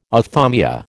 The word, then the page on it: ophthalmia: American English